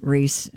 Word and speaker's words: Rhys: Oh, it is Rhys.